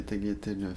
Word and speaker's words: tagliatelles: Je fais des spaghettis et des tagliatelles fraîches aux œufs. – Hein ? Je prononce pas le G. Tagliatelles.